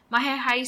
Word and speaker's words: Majayjay: Mahayhay spelled like Majayjay.